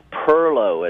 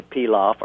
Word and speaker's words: perlow: It is a simple bean and rice perlow, as we say. Perlow is, most people would say pilaf or pilo or pilau, or there's many pronunciations. In Charleston, you also hear perlow, but it's a bean and rice dish.